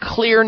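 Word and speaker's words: Xlear: And it's a tall order unless you happen to know about Clear nasal sprays. Clear is spelled with an X, X-L-E-A-R. The X is for xylitol, which is one of the many ingredients that are in these natural, safe, and effective, science-based nasal sprays.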